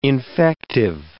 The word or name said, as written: infective